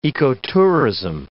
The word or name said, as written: ecotourism